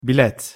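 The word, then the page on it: bilet: Turkish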